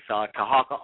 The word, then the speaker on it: Tarhaka: And that is Tahaka Amana Elbe. It is spelled T-A-R-H-A-K-A. Amana A-M-M. I'm sorry, A-M-A-A-N-A, and then L-B-E-L, and then B-E-Y.